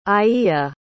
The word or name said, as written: aiya